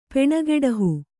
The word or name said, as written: ಪೆಣಗೆಡಹು